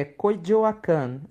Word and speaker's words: Coyoacán: Acredita-se que esse filme tenha sido gravado nas partes coloniais de San Ángel, Coyoacán. Falei certo? – É, Coyoacán, a terra onde nasceu Frida Kahlo.